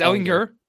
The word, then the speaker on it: Ehlinger: No, I think Sam Ehlinger, what is it? Is it Ehlinger? Right, it's Ehlinger. Ehlinger. Thank you. Okay. – Ehlinger. – And, Sam Ehlinger. You don't like pronounce the G. It's Ehlinger. Yeah.